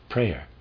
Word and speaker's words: Preyer: I think his name is probably pronounced Preyer, P-R-E-Y-E-R, I'm going to say Preyer. Preyer then took up the works of Mesmer with complete goodwill and could find nothing in them but empty words.